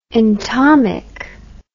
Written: entomic

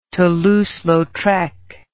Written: Toulouse Lautrec

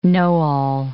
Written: know-all

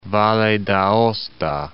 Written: Valle d'Aosta

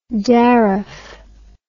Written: daraf

